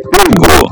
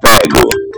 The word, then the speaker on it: pego: Mas com a pronúncia fechada, pego. Evite a pronúncia aberta. Ele foi pego.